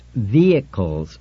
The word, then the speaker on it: vehicles: She said brightly when he had finished reading, it's pronounced vehicles, Richard. No H. Accent on the first syllable.